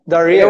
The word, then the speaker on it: Darrieux: Darieux.